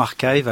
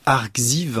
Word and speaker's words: arXiv: Exactement, Archive. Alors en français, on dirait Arxiv, hein, puisque ça s'écrit A-R-X-I-V, grand X-I-V, mais je vais quand même prononcer Archive, c'est beaucoup plus classe. Archive qui est présenté parfois un peu abusivement comme le Wikipédia de la recherche scientifique.